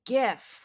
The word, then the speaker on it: GIF: Newsweek declares it's GIF with a hard G.